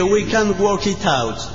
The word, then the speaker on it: We Can't Work It Out: Fernando de Cañuelas pide We Can't Work It Out.